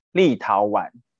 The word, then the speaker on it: 立陶宛: 是立陶宛。 – 嗯，没错，不要念成怨哦。 – 我还我还要打开小抄，不然我可能会讲错。不会有人念成立陶院吧？ – 就少看上面了，少看了宝盖头。 – 好，那这个新闻是。就是录影的昨天才传出来说，这个立陶宛将要捐赠台湾两万剂的 AZ 新冠疫苗，那预计在九月底前会抵台。